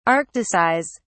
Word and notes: arcticize: British English

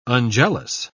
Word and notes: unjealous: British English

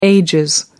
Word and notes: ages: British English

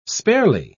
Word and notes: sparely: British English

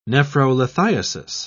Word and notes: nephrolithiasis: British English

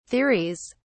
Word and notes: theories: British English

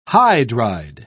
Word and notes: hydride: British English

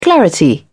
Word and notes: clarity: British English

